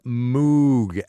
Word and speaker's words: Moog: When you talk electronic music synthesizers, there is really only one name you need to know, Bob Moog. Actually, it's spelled M-O-O-G, pronounced Moog.